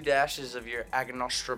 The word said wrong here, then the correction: Angostura: bitters. Angostura. I always fucking pronounce them wrong. Angostura.